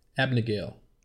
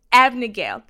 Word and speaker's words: Abagnale: Abagnale. – Abagnale, thank you.